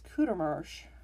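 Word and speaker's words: Courtemanche: Bernice Kudermarsch, and yes, that is how it's pronounced by the family according to the Ginsburg book, was last seen on May 30th, 1984.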